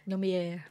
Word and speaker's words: Lumière: Lumière.